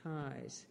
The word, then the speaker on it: Keyes: Spell it. – K-E-Y-E-S. And yeah. – Pronounced Keyes? – Keyes, yeah.